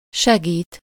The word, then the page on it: aider: France (Paris); [e.de]